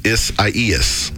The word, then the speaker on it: Isaias: The storm is called Isaias. It's spelled I-S-I-A. I done messed it up. I-S-A-I-A-S. Isaias.